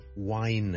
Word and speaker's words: whine: 例如 ,WHINE, wine, to whine about something, 抱怨啊发牢骚，我们叫做 whining。咁有些人就将他读成呢个音好像 whine。咁但係呢又聽到好多人呢平時以前自己學呢就係同 wine 呢 ,wine, 酒為紅酒咁啦 ,red wine, wine, 就同一個音嚟。